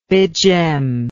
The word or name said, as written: begem